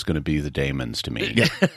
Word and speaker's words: Daemons: I always wondered whether to pronounce it Daemon or Daemon, but I guess it's the way they said it in the episode, it was the Daemons.